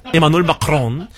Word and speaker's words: Emmanuel Macron: Ese sí sé que se pronuncia así porque Ebrard lo dice a cada rato. Emmanuel Macron.